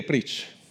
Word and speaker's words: preach: Cosa vuol dire, cosa vuol dire preach? L'ho detta male. Ti distruggono anche il poco inglese che sai. Dove sei? Ah, fammi sentire la pronuncia giusta. Vedete, preach. Per me vuol dire fai fretta che dobbiamo andare.